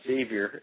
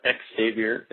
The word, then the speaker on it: Xavier: Yeah, it's pronounced Xavier. It's not X-Xavier.